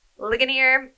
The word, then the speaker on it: Ligonier: Ligonier Ministries.